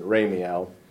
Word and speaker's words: Ramiel: Uriel, Raphael, Raguel, Michael, Siriel, Gabriel, and Ramiel, or Ramiel, probably. That's how you pronounce it, Ramiel.